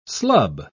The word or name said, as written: slub